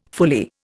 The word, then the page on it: fully: Indian